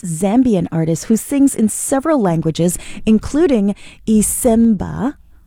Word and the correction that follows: Isibemba: I think I said that right. Isibemba. No, I didn't say it right. So she sings in Isibemba, her native tongue, English, French, and Lingala.